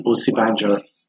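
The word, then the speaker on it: Osinbajo: But it's it's not Obasanjo. – No. – Isn't it Osi Banjo. Osi, woo. – Yeah. Wow. So it's Mike Pence for the US and Yemi Osibanjo.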